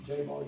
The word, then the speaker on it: Jabal: That's how it's pronounced in the Hebrew. Jabal, however you want to say it.